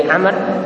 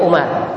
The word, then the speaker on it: Amr: Ingat kalau ada sama ain mim ra tapi di belakangnya itu ada waw, itu bacanya Amr. Tetapi kalau ain mim ra tidak ada wawnya, itu bacanya Umar. Di sini bacanya Amr.